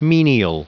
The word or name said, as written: menial